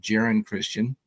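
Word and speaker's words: Geron Christian: Jaron Christian, by the way, a lot of people call him Jaron Christian, but I heard him pronounce his own name on a video, and he pronounces it Jaron Christian.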